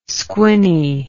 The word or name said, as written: squinny